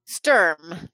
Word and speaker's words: Sturm: Sturm, yes. Sturm. Rhymes with worm.